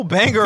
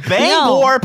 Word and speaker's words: Bangor: Local banger resident. local resident of Bangor, Pennsylvania. – That's how they say it? – No, but that's how people that don't know banger say it.